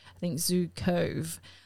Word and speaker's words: Zukav: Now, I could totally have pronounced that wrong, but I listened to the American pronunciation of his name. So it's spelled Z-U-K-A-V. So I want to say Zukav, but they say Zukov.